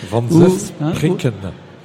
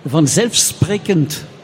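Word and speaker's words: vanzelfsprekend: Et la difficulté en néerlandais, c'est la prononciation. Pour un Français, c'est un peu difficile, la prononciation. Hein, par exemple, je ne sais pas, moi, van zelfsprekend, ça veut dire évidemment. Bon, il faut savoir le dire.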